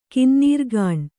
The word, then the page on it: ಕಿನ್ನೀರ್ಗಾಣ್: kannada